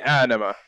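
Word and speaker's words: Ænima: The A and the E together is pronounced as if you literally just say the letters at the same time. It's eh. – Okay. That's nice. – So it'd be Anima.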